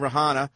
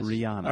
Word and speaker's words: Rihanna: Why do you keep pronouncing the Rihanna? – H? – I don't know. Was it Rihanna? – Yes. – Rihanna.